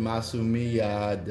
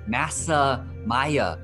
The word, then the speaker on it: Masumiya: I actually had a friend who's Japanese American that mispronounced all the vowels. He said Masa Maya.